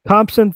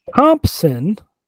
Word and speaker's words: Thompson: Thompson, which I have to make sure to pronounce the P each time.